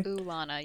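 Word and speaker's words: Oolana: because there is three O's. It is O-O-O-L-A-N-A. So I think it's Ulana. It's like, you know, oogenesis?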